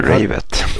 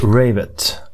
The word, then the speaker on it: Ravett: Peter Ravett, eller Ravet, osäker på hur man uttalar det. – Han, Ravett. – Ravett.